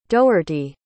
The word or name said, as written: doherty